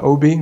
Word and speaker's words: Obi: Alternatively, you can go to my Instagram, which is Ikenna Obi, which is my first name plus my last name, which is OBI.